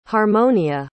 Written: harmonia

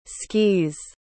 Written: skees

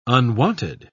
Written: unwanted

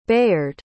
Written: bayard

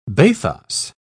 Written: bathos

